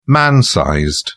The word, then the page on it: man-sized: British English